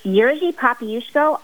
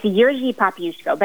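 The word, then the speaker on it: Jerzy Popiełuszko: help me out, Jerzy Popielusko? – It's Jerzy Popielusko. I am Polish, and yet it took me several weeks of listening to lots of pronunciations myself to get it right.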